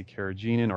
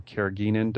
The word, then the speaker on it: carrageenan: So you'll see sometimes bar gum, you might see carrageenan or carrageenan, depending on who you ask, whether that's a hard or soft G.